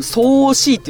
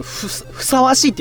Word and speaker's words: 相応しい: はい、あの僕一部相応しいって言ったけどふさわしいって読むらしいですね。